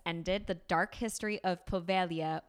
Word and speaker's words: Poveglia: I do take Italian. It would be Povelia. Oh, well, whatever. Don't tell Povelia.